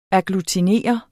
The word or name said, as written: AGGLUTINERE